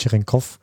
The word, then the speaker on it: Cherenkov: Ähm, Cherenkov ist eigentlich ein, äh, ist ein Name von einem Wissenschaftler, das war ein russischer Wissenschaftler und Aussprache ist Cherenkov.